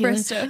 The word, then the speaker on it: Bristow: I always say she looked like Caitlin Bistro. How do you say her last name? Caitlin Bristow.